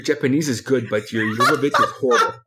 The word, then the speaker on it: Jovovich: I'm so disappointed you didn't say Milla Jovovich. – I didn't want to mispronounce it. I know you just did. It's, it's Jovovich. It's Jovovich.